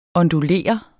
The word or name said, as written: ondulere